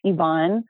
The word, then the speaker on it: Yvonne: But it's Yvonne, Y, V as in Victor, O, N as in Nancy, N as in Nancy, E, Yvonne.